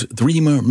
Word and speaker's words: Threema: If you desire a more secure, well, somewhat basic communications app, pony up the equivalent of $4 and use Threema Messenger. That's spelled like it sounds, Threema.